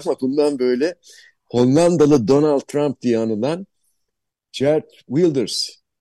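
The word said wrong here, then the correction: Geert: Gert diye okunuyor değil mi? – Hert Wilders. – Hmm. Hert.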